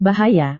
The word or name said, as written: bahaya